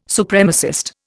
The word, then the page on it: Supremacist: Indian